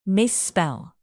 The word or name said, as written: misspell